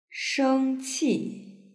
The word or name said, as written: shengqi